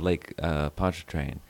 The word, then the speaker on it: Pontchartrain: I've been many times over the years, and I have some relatives who live on the other side of Lake Pontchartrain.